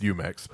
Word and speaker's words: Jumex: Is that what the Juju? – Oh, for sure. – Oh yeah, for sure. – And I'm pretty sure that's not how you pronounce it, but whatever. – No, it's Jumex.